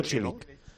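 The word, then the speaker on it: Čilić: ¿Ves? Se dice Cilic. – Cilic.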